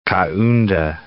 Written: Kaunda

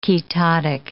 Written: ketotic

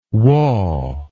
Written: wa

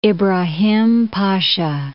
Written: Ibra.ham Pasha

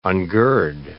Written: ungird

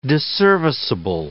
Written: disserviceable

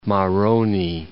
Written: Maroni

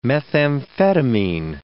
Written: methamphetamine

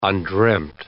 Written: undreamt

